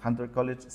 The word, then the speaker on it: Hunter College: Ella es guionista, graduada en artes de Hunter College City University of New York. ¿Está bien? ¿Lo dije bien? – Sí, perfecto.